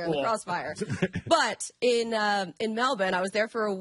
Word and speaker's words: Melbourne: Like, just unbelievable that they tried to pull off a game after just landing in, I'm going to call it Melbourne. Because that's how they say it there.